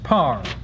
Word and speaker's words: power: In the following line, she introduces an extra syllable by using the disyllabic pronunciation of power, P-O-W-E-R, which should have been, she says power, it should have been pronounced par.